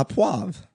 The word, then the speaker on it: au poivre: So the first night we were there, they made steak au poivre. – Oh, yeah. A poivre. – A poivre? – Yeah. – How do you say that? – A poivre.